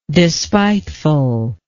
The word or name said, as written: despitefulness